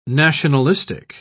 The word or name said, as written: nationalistic